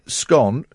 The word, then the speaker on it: scone: The survey also revealed the preferred way to say scone rhymes with gone.